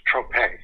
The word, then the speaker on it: Trope: So you got The Melting, and then, pardon my pronunciation, the second one, is it pronounced Tropas? – It's Trope. – Trope. My mistake. – Yeah. Very French sounding.